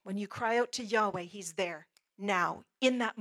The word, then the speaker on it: Yahweh: God's name, we pronounce it Yahweh, even though there's no vowels in there.